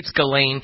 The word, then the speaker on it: Ghislaine: I think it's Ghislaine. It's like G-H-I-S, right? L-A-I-N-E, something like that. Yeah, Ghislaine.